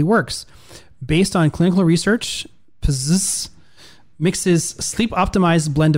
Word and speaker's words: Pzizz: Bzzz. This is spelled P-Z-I-Z-Z. How would you pronounce that? – I think you nailed bzzz.